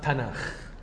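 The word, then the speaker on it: تناخ (תנך): الكاف دي بقى ساعات لما بتيجي في اخر الكلمة على حسب، ممكن تتنطق كاف او ممكن تتنطق خ، واضح ان هما هنا كانوا بينطقوا خ، فيبقى لما اخد التاف مع النون مع الكاف تبقى هم ينطقوها تناخ.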